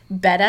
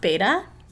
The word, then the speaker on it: betta: Like betta fish, which by the way, I learned you pronounce that betta and not beta.